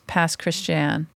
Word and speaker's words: Pass Christian: And then the actual pronunciation is Pass Christian.